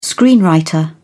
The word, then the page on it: screenwriter: British English